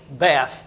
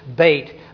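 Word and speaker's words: Beit (בית): We say in English, Beth. The Hebrew pronunciation is Beit.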